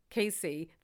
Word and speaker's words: KSEE: How do you pronounce that? K-S-E-E. – Casey.